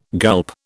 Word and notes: gulp: British English

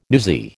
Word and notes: newsy: American English

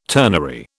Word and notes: turnery: British English

